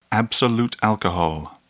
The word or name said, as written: absolute alcohol